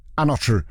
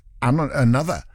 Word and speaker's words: Anotru: A-N-O-T-R. It's not Another. It's Anotru. Anotru. Featuring 54 Ultra. If we're saying it as it's written, Anotru. But then, you know, it's only dance music artists that do that. Go, well, I know how it's spelt, but it's pronounced Another.